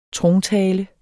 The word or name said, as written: trontale